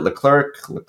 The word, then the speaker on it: Leclerc: And by the way, how do we pronounce his last name? I've heard it Leclerc. – I always go with Leclerc. – All right, let's go with that.